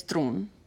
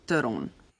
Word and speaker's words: Theron: My last name in South Africa is either if you're South African, it's Tron. And if you're English South African, it's Theron.